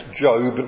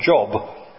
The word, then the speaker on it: Job: Note it's pronounced Job, not Job.